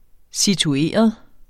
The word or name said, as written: situeret